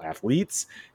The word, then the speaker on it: athletes: Let me put the emphasis on the right syllable, athletes.